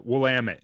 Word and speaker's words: Willamette: How do you say that river in there? – Willamette. Yeah. Some people say Willamette or Willamette. – Oh, it's W. – Yeah. Willamette. Okay. Yeah.